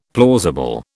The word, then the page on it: plausible: British English